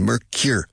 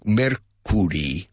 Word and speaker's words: Mercury: Fijaos en la pronunciación. Mercure. No, Mercury. Mercury. No, Mercure. Mercure. The first syllable and the second syllable sound identical. They rhyme.